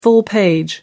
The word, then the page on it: full page: American English